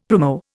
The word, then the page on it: primo: Indian